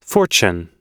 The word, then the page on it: fortune: American English